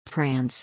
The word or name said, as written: prance